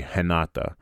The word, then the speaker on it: Renata: And her name was like Renata, but I think in Portuguese it's actually Renata, because you pronounce the R's like H's.